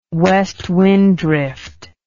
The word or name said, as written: West Wind Drift